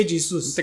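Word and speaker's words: Jesus: اعمال هي جيسوس ولا خيسوس؟ هي جيسوس انت قاعد تقول لي جيسوس هاي آه، هي جيسوس هي جيسوس لانه في البرتغاليه الجي دي بتتنطق جا جا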